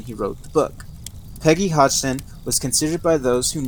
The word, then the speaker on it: Hodgson: At this time, it was occupied by a family consisting of 47-year-old divorcee Peggy Hutchinson and her four children. I'm sorry, actually her last name is pronounced Hodgson. I don't know why I got the Simpson.